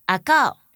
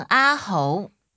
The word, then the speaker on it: 阿猴: 屏东原来是一个原住民发音的地名，它的发音类似于阿告，也有人把中文写成阿猴。